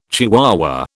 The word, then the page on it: chihuahua: British English